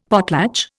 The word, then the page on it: potlatch: Indian